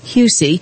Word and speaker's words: Huessy: His last name is spelled H-U-E-S-S-Y, Husey.